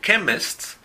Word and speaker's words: chemist: Por ejemplo, chemist, que es farmacia, se escribe CH y en ese caso el sonido es K.